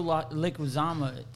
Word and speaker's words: Leguizamo: To me, it's Joe Leguizamo. That's how you say his name.